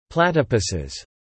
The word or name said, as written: platypuses